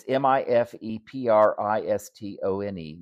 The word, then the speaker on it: mifepristone: It's M I F E P R I S T O N E, mifepristone.